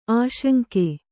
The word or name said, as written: ಆಸಂಗ